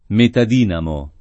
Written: metadinamo

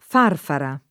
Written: farfara

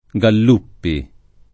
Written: Galluppi

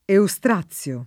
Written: Eustrazio

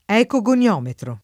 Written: ecogoniometro

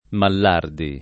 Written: Mallardi

